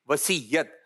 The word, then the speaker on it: وصیت: صحیح جو ادائیگی ہے صحیح تلفظ ہے یہ ہے وصیت ہم نے کیا کرنی ہے وسیعت کرنی ہے.